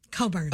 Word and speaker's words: Cockburn: Bruce Cockburn, the writer of Coburn. – Oh, sorry. – It's okay. – It's spelled funny.